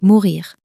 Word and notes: mourir: french

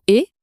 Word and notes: et: French

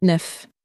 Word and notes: neuf: french

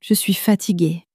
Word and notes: je suis fatigue: french

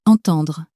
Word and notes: entendre: French

